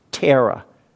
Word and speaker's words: Theravada: it's spelled Theravada, but it's pronounced Thera.